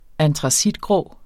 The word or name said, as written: antracitgrå